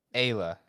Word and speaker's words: Ayla: Elia is going to be Evil Genius's support. – Elia or Ayla? – Ayla. L-A-A. Yeah, Ayla. I said it wrong.